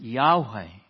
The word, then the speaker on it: Yahweh: And we pronounce it Yahweh.